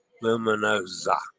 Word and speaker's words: Luminosa: The S in luminosa is pronounced with a Z, luminosa. In Italian, the single letter S sounds like Z. Okay, luminosa instead of luminosa. I said that wrong.